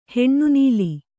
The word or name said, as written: ಹಿನ್ನೆಲ